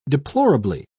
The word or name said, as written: deplorably